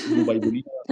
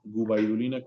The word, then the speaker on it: Gubaidulina: Gubaidulina, ¿cómo se escucha? Gubaidulina con G y con B, con B labial, Gubaidulina.